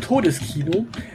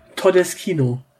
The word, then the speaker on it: Todeskino: Äh, Peter Todeskino äh, ist allerdings schlecht ausgesprochen, denn er heißt eigentlich Todeskino.